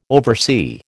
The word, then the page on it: oversee: American English